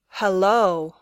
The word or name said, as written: hello